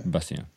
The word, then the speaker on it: Bastien: I say your name, Bastien. – Bastien, in French, Bastien. Yeah. – Bastien. Okay. Yeah. – It's like Sebastian. – Yes. – But without the first syllable.